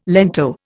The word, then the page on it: lento: Indian